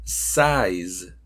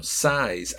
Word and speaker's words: size: Size, que es el tamaño, size. Ahí tenemos en size, que es tamaño, tenemos los dos sonidos juntos.